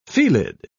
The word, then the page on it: felid: British English